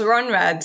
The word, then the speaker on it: Rhönrad: But, but you won sixth place in the Rhönrad World Championship. Is that how you say it? – Yes, yes, Rhönrad.